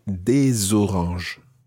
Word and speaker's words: des oranges: And if it's some oranges, then you would say des oranges. – Des oranges. – And of course, you pronounce the S of des because it's followed by a vowel.